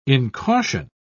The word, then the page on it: incaution: British English